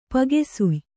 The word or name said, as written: ಪಗೆಸುಯ್